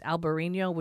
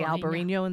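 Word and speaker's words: Albariño: And the only one that most people, I think, would have any idea is Albarino, which is really Alvarinho. They pronounce it different, but it's the Albarino in Spain. There's a V and an H. In the same Alvarinho in Portugal.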